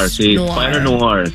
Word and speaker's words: Spider-Noir: Spider-Noir.